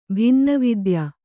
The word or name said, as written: ಭಿನ್ನವಿದ್ಯ